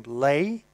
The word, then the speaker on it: Leahy: The Americans pronounce that surname Lay, but Martin Leahy is a prominent fella in the music biz back in Ireland.